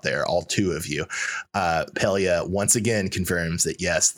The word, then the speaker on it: Pelia: How do you say her name? Pelia? – Pelia. – Pelia.